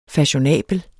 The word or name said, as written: FASHIONABEL